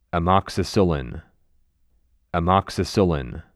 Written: Amoxicillin

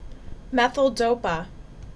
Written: Methyldopa

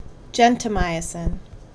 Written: Gentamicin

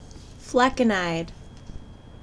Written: Flecainide

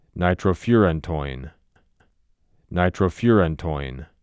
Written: Nitrofurantoin

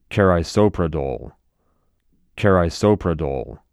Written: Carisoprodol

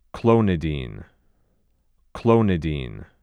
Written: Clonidine

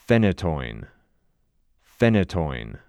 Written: Phenytoin

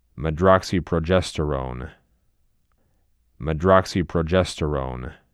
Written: MedroxyPROGESTERone

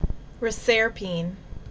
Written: Reserpine

